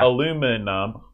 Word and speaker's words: aluminum: Aluminium, that's five syllables. – Yeah. – Aluminum, or in West Virginia, aluminum, that's three.